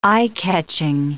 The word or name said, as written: eye-catching